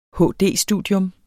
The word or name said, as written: HD-studium